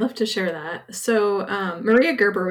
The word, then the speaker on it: Maria Gerber: And we have with us this month and she is bringing us the story of Maria Gerber. Did I say that right? – You did.